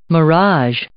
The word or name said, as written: mirage